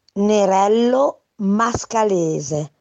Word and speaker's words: Nerello Mascalese: The pronunciation is Lavico Nerello Mascalese.